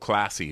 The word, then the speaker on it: Klasse: And so they go to this Ferrari dealer and it's called Classy, but it's spelled K-L-A-S-S-E.